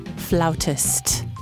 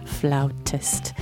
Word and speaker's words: flautist: Herbie Mann, jazz flautist. I believe is how you say it, flautist.